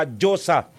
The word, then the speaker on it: Diyosa: Ano ang pag-pronounce? Diyosa. Ah, Diyosa. Okay. Pinkorek po ako ni Miss Kanaman Angelica Salcedo. Diyosa. Yan. Diyosa. Bakong Diyosa. Diyosa. Okay. I stand corrected.